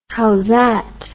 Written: howzat

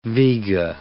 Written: Vega

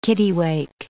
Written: kittiwake

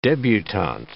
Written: debutant

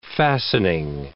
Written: fastening

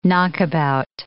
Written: knockabout

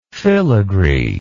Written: filigreed

